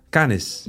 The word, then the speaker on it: Canis: De C. Spreek je uit als een K. – Kennis. Canis.